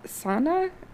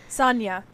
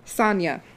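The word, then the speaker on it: Saña: And I think it's pronounced Sana? It's S-A-N-A, but there's a little over the N. – Sanya. – Sanya. – The tilde makes a nya sound.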